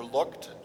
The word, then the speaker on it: Lucht: My last name is Lucht. I'm Pastor Lucht. You pronounce it like what you did out the window.